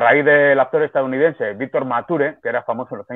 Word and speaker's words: Mature: Sí, sí, decir que es Mature, ¿no? Como, como se escribe, se lee, es Mature. Eh, mucha gente usa el inglés y dice mature, pero, pero no, es mature.